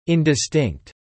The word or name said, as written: indistinct